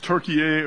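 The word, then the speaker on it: Türkiye: Is it pronounced Turkey A or is it still Turkey? It's spelled Turkey A, T-U-R-K-I-Y-E. – I believe it is.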